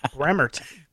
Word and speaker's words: Bremerton: Bremerton. – Pacific Northwest. There must be a lot of rain. – There's an MXPX song called Move to Bremerton. So that's how I knew how to pronounce it properly.